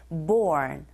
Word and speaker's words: born: 자, 본, 본 아니고 R이 들어가 있기 때문에 born. R 소리 발음하실 때 혀가 어디에도 닿지 않게 조심하시고요.